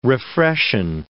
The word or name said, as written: refreshen